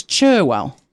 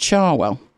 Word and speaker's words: Cherwell: The issue with it is it's spelt with an E, so you would think it was Cherwell, but it's pronounced Charwell.